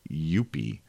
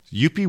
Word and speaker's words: Youppi: Y-O-U-P-P-I, Youppi.